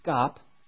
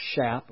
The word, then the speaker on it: Schaap: Pastor Jack, I think his name is pronounced Scop, looks like Shap or Shop, S-C-H-A-A-P, First Baptist Church, Hammond, Indiana.